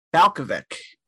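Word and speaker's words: Balkovec: Rachel Balcovic. Is that correct? Did I say her name right? – I believe that is the pronunciation.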